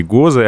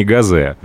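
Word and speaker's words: Gose: Я намеренно так произношу, потому что считаю, что все-таки ГОЗы, а не ГАЗе. Ну, во-первых, потому что в немецком языке это слово именно так произносится, угу.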